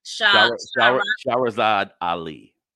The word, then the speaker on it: Shahrazad Ali: Shahrazad Ali.